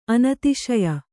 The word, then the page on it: ಅನತಿಶಯ: Kannada